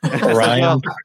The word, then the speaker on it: Orion: Our other co-host couldn't be here tonight, but he had asked me before you came on, does he pronounce it Orion Press or Orion Press? – Orion.